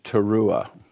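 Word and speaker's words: teruah (תרועה): Teruma, which means contribution, there's another word which is very, very close in the pronunciation of this word. It's teruah. Teruah versus teruma. Very, very slight difference.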